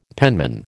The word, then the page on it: penman: American English